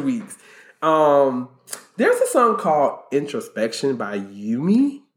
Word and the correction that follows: Umi: Umi? – Oh, yes. I see her name wrong. Oh my God, what is it? – Well, it's Umi.